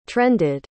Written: trended

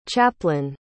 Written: chaplin

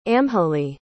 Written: amhlaidh

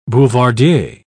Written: boulevardier

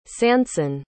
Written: sanson

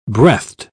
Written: breathed

